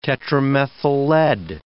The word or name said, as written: tetramethyllead